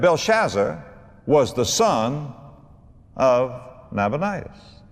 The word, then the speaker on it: Nabonidus: It was a man named Nabonidus or Nabonidus, whichever way you prefer to pronounce it. Nabonidus is the way I'll pronounce it.